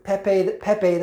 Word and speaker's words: Pepe: And the Q plan and the Anons are also Peep the Frog, Pepe the Frog, actually, I should say that correctly.